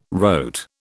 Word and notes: wrote: British English

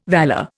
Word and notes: valour: Indian

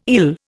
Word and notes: ill: Indian

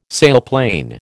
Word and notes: sailplane: American English

